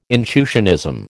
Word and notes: intuitionism: American English

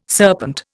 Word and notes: serpent: Indian

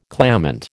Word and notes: clamant: American English

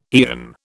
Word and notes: aeon: British English

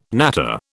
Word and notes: natter: British English

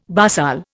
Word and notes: basal: Indian

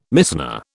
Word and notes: Mishnah: British English

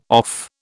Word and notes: off: British English

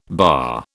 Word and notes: BAA: British English

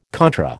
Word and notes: contra: American English

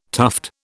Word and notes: tuft: British English

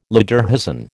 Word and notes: lederhosen: American English

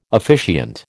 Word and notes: officiant: American English

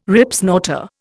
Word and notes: Ripsnorter: Indian

